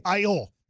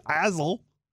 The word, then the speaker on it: aisle: They have a silent A and a silent S? – Yeah. – It's not like they're saying Azzle.